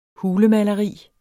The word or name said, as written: hulemaleri